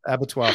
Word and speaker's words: abattoir: They play Mozart in, how do you say that? Abattoir? – Abattoir for a reason.